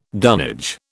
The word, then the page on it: dunnage: British English